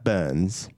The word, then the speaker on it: BRNS: Go on, Uh, right, this BRNS in capitals, but I assume you pronounce that Burns. Burns. But it's spelled without the U, basically.